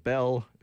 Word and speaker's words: Behl: Before that, an old, old band from Winnipeg, and I do know how to pronounce them. It's called Bell. It's spelled B-E-H-L.